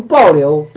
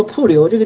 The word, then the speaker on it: 瀑流: 比如暴流，暴流就是山洪爆发，水暴涨了就是暴流。有时候其实是应该是读作瀑流，这个经文上原经文上是瀑流，